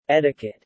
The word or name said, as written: etiquette